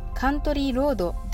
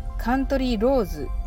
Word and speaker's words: Country Roads: まずカントリーロードではなくカントリーローズと最後に S の発音がまず大事です